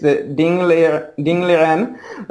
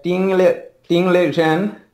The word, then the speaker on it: Ding Liren: Okay, I know it, but the problem is that the international pronunciation differs quite a lot from Chinese one. In the English transcription is Ding Liren. Chinese pronunciation is probably something like Ting Li Zhen or something like this, somewhat different.